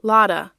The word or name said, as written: Lotta